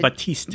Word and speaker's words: Baptiste: We've already experienced, Baptiste. – It's Baptiste. – Baptiste. – No P sound is what I found out when I looked up the pronunciation of the word.